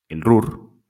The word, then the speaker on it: Ruhr: ocupar el RUR. ¿Sí se dice así, Luis? Sí.